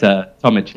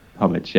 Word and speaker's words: Tomic: And it's Tomic, Larry. – Is it Tomic? Have I been saying your name wrong this whole time? – Everyone does, don't worry. – Tomic. – Tomic, yeah. No one's ever got it right first time.